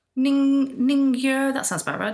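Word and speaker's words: Ningyo, that sounds about right (人魚): Ningyo? Ningyo? Does that sound familiar? – Ningyo. – The entire country with that pronunciation. – Ningyo. – I'd say Ningyo, yeah. – Ningyo, that sounds about right, doesn't it?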